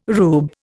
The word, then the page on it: rube: Indian